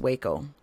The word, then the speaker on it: Wako: But I think it's Waco, W-A-K-O, University in Japan.